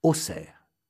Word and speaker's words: Auxerre: Eh oui, on prononce Bourg avec un K, alors que ça s'écrit Bourg, B-O-U-R-G. Auxerre. On prononce Auxerre, Auxerre. Il y a un X pourtant.